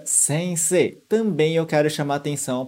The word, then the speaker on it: sensei: A gente escreve esse i no final, mas na verdade a gente não fala. Presta atenção na minha pronúncia. Sensei. Sensei. Reparou? Eu falei, na verdade, como se fossem dois E's no final.